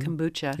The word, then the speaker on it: kombucha: Am I pronouncing that right even? – Kombucha.